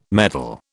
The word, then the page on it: meddle: British English